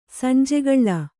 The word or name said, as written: ಸಂಜೆಗಳ್ಳ